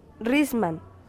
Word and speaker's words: Rizman: Name, please, Dr. Jeff Risman. – How do you spell its last name? – Risman, R-I-Z-M-A-N.